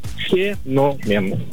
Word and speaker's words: феномен: На самом деле у этого слова есть два варианта ударения. Все зависит от значений. Так, если это слово употребляется в значении что-то необычное, особенное, явление, редкий факт, да, тогда феномен. Все правильно, феномен.